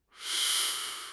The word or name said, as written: shu1 shi4